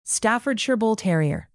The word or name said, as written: Staffordshire bull terrier